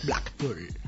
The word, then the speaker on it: Blackpool: Blackpool. – Lo he dicho bien. – Muy bien. ¡Bravo!